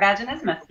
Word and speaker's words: vaginismus: This this concerns a condition called vaginismus. Am I saying that right? All right, cool. – You are vaginismus. Yes.